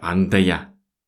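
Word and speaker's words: anda ya: Андая. Андая. Мы вот именно так это и произносим.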